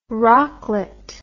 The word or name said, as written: rocklet